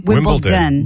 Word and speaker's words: Wimbledon: Wimbledon. – Wimbledon. – Wimbledon. – Yeah, I got D-O-N. – Corrected. – Wimbledon? – It's D-O-N. – Like Downton. – Okay, hold on. Say Wimbledon.